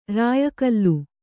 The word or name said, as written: ರಾಯಗೋಲು